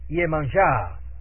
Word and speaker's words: Yemanjá: Los orishas del lucumí afrocubano son prácticamente los mismos allí, pues se adoran, pero con otros nombres, que por supuesto se pronuncian aportuguesadamente. Y así, Xangô llega a ser Xangô, y Yemayá es Yemanjá, etc.